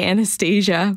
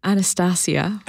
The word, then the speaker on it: Anastasia: How do you say it? – I say Anastasia. – But your director? – Anastasia. – Anastasia. Yeah. Okay. So either way is acceptable.